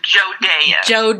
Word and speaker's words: Jodaea: It is pronounced Jodaea.